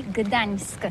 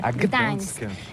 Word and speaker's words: Gdańsk: Oui, c'est pas très facile pour des étrangers, mais remarque bien que la langue polonaise, c'est la langue où on prononce chaque lettre qu'on écrit. C'est pourquoi on dit Gdańsk. – Gdańsk.